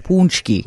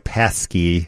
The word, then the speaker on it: paczki: If you're from Chicago, you know, even though it's spelled P-A-C-Z-K-I, it's punchki. – Right. It looks like pasky. – Right. – But it's not.